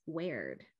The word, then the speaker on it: weird: In fact, in the first folio, the earliest surviving text of Macbeth, the word is variously spelled wayward, weeward, wayard, all of which would have been pronounced the same way in 1606, weird.